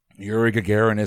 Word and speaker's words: Gagarin: How do you pronounce his name? Gagarin? – Yuri Gagarin is correct.